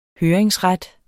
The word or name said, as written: høringsret